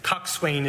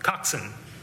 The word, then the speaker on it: coxswain: It said, and I had the pronunciation wrong originally, but it said, what is a, I'm going to say it wrong again, coxswain is how it's pronounced, but it rhymes with dotson, coxswain.